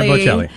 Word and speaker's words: Bocelli: Andrea Bocelli.